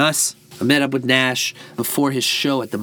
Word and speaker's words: Gnash: Of course, that's Gnash spelled with a silent G at the front.